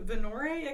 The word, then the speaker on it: Venorrae: I can spell it for you if you'd like. – Please. – V-E-N-O-R-R-A-E. – Oh, God. – Fantasy spelling. – I put it Vino Ray. Okay. V-E-N-O-R-R-A-E. – Yes.